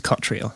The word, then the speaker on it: Cottriall: Eh, ik hoop dat ik, I hope I uh, pronounced this correctly. – Very close. James Cottrell.